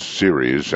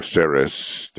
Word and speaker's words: Ceres: It's Ceres and or Ceres, depends on how you want to pronounce it.